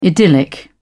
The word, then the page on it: idyllic: British English